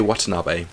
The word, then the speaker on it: Watanabe: So we've got Ordinary Gods number 1 by Kyle Higgins, not Ryan or Brian Higgins, Kyle Higgins, and Felipe Watanabe. No clue I pronounced that right. I'm going to roll with it. – Yeah. – Probably. Yeah, you got it right.